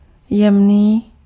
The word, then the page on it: delicate: English